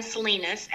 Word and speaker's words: Salinas: But on Facebook, my name is spelled AUNDREA, Victoria and Salinas, SALINAS.